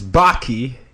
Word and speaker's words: Bakke: Today, I have with me Chris Bakke. Actually, rhymes with hockey.